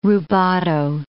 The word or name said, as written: rubato